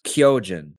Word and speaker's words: Kyojin: Today's Lessons is going to be the Japanese word for Titan, and that is Kyojin. Kyojin is spelled K-Y-O-J-I-N.